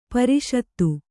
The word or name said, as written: ಪ್ರಸ್ತ